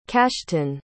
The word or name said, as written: kashton